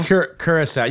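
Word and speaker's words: Curaçao: Curacao? – Curacao.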